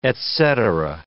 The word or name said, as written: etcetera